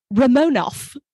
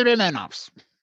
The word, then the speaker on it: Romanov: So it's Romanov. – Yes. – The Romanovs. Instead of, no, it's Romanov. They're the Romanovs. Stop calling them that.